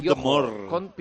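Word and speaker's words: Johor: Es que no sé cómo se pronuncia. Príncipe de Yohor.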